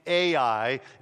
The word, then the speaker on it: Ai: is sent down to Ai, is how I pronounce it, down to Ai, which is the next conquest.